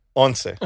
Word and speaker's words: once: How you say it? – Once.